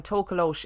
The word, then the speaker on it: Tokoloshe: Just before we go any further, so I looked this up, and Tokolosh is just the most commonly used pronunciation, but there are lots of other pronunciations of Tokolosh.